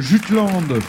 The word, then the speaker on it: Jutland: Oui, on peut le prononcer de cette façon en français, le Jutland. En danois, ça donne quelque chose comme Jylland. Je ne suis pas sûr de prononcer parfaitement le danois, je le confesse, mais je crois que c'est entre Jylland et Jylland. Mais en français, disons Jutland. C'est cette partie continentale du Danemark.